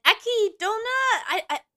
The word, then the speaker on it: Echidna: And Ekidona, it's spelled Echidna, but I know that's not how you pronounce it.